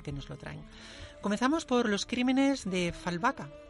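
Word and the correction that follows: Fjällbacka: ¿Se pronuncia así? – Eh, se pronuncia Fialbaca. – Fialbaca. – Es que es sueco. Es sueco.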